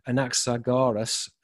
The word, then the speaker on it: Anaxagoras: let me pronounce his word, his name correctly, Anaxagoras, the princely speaker.